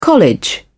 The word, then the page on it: college: British English